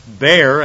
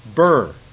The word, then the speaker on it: bier: So the old one said it was pronounced bear, and it's based on the fact that they bear him. And the newer dictionary said it's pronounced burr. So whichever one you want, you go ahead and help yourself. But I'm gonna pronounce it bear. To me, it looks like beer, B-I-E-R.